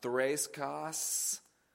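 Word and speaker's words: threskeia: It's the Greek word that's pronounced thraiskos.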